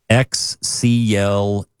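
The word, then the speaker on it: Xcluder: Excluder.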